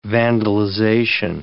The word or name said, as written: vandalization